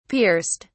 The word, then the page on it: pierced: British English